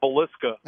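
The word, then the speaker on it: Villisca: Yeah, my cousin, because I was trying to type in the GPS, and I was like, Vasilla Murder House, and he was like, you're never going to find that because it's called Velisca.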